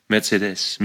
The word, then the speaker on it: Mercedes: Und du müsstest auch nicht Mercedes sagen, sondern Mercedes. – Ja, hab's nicht gesagt, Mercedes? Mercedes. – Nein, du sagst Mercedes. Und musst auf die letzte Silbe. Mercedes.